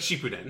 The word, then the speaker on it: Shippuden: Shippuden.